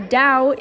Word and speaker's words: Dao: Another weapon the ancient Chinese used was called the Dao, which rhymes with wow. It's different from the Jan because the Jan is a two-edged sword while the Dao is a saber,